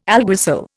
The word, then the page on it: alguacil: Indian